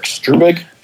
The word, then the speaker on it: Struebig: My name is Erik Struebig. – Struebig. I got it right then.